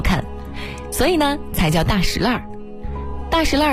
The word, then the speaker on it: 大栅栏: 比如说前门外的大石烂是极为著名的闹市，俗称大石烂。那为什么要叫大石烂呢，而不叫大栅栏？其实啊，经过考究，它的读音呢属于地名的特殊读音。历史悠久的大石烂读法呢属于保留古音的读法。